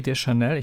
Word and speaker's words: Deschanel: Was ich auch sehr schön finde, ist ja, dass die äh, Zoe Deschanel, ich glaube, so spricht man den Namen der Hauptdarstellerin aus, genau.